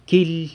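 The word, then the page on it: quily: San Lucas Quiaviní Zapotec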